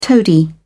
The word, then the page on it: toady: British English